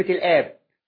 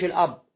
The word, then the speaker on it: الآب: وفرق بين كلمة الأب وكلمة الآب. كلمة الآب عندهم هم اللي هو الآب، ما بيسموش الأب، بيسموه الآب، عشان برضو لما تناقش صوتيا تنطقها مظبوط، الآب والابن والروح القدس، مش الأب والابن والروح القدس.